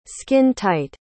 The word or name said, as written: skin-tight